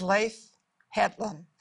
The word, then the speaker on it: Leif Hetland: And it is Leif Hetland.